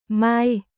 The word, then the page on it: Mai: Vietnamese